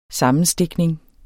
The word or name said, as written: sammenstikning